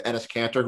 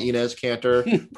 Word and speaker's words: Enes Kanter: Uh, I'm gonna keep mispronouncing his name in the vein of Enes Kanter, who I called Inez Kanter probably for four months.